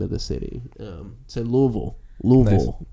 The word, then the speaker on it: Louisville: Say Louisville again. I like Louisville. That's how they say it there.